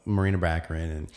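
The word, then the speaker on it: Morena Baccarin: She's got to go, you know, maybe he spends some time dating, you know, Marina Baccarin.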